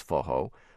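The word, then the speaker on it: forró: based on the Brazilian style known as Fojo. By the way, that's spelled F-O-R-R-O.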